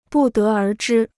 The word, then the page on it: 不得而知: bù dé ér zhī